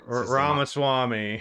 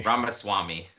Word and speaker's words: Ramaswamy: Ramaswamy. – Ramaswamy.